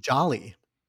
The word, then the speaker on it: Djali: It's Jolly. – Jolly? – But it's spelled D-J-A-L-I.